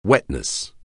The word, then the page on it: wetness: anglais